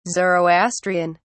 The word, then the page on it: zoroastrian: British English